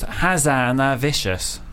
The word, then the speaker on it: Hazanavicius: It actually is pronounced Hazanavicius. Hazanavicius.